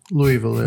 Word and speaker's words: Louisville: Wie, wie spricht man diese Stadt aus? Louisville? – Louisville, ja.